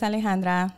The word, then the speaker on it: Alejandra: So, I was telling someone the other day, I'm like, oh yeah, my name is Alejandra. Now you have a reason to roll your R's.